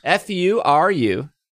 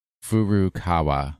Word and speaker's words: Furukawa: AR technology company Niantic, headquarters in San Francisco, CEO John Hankey, and Nintendo, headquarters in Kyoto, President Furukawa? I said that wrong. I'm sorry. – How is it spelled? – Furukawa. – Furukawa. – Yeah, Furukawa. – That was way better than I did.